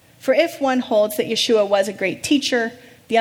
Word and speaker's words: Yeshua: What intolerance for a religion to accept Yeshua, that's the Hebrew pronunciation of Jesus' name, to accept Yeshua's claim to be the only way to God.